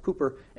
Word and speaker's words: Cowper: it's spelled Cowper, but it's pronounced Cooper, and David Brainerd.